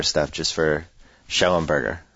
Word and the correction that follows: Schnellenberger: Wait, Schnellenberger. Schnellenberger. There's an N in there, right? In the beginning. – Schnellenberger.